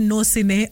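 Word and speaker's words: Gnossienne: And that feeling when you feel for somebody is called नोसिने. जी, द स्पेलिंग इज GNOSSINNE. नो सिने, यस.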